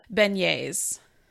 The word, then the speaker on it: beignets: Beignets. – Beignets. Yeah. I'm Oklahoma, so. – You got to get that slight French twist on it, the beignets.